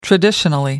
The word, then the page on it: traditionally: American English